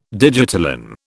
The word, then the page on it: digitalin: British English